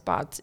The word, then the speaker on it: Botz: Every time for announcing, usually people pronounce Botz incorrectly. So I've gotten like Butts and Boats, but my favorite one was Boss. I was like, I don't know how you missed the T, but I'll take it. I yeah, like that boss, mispronunciation.